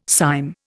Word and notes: cyme: Indian

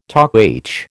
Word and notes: Toc H: American English

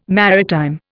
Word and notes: maritime: Indian

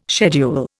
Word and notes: schedule: Indian

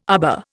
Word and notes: arbour: Indian

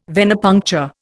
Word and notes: Venepuncture: Indian

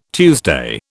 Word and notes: Tuesday: British English

